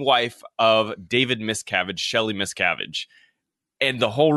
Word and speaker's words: Miscavige: And I also think he butchered the last name, which even made it a little more confusing for people. He said like miscarriage or something. Like he didn't say the name properly.